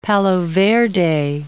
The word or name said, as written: palo verde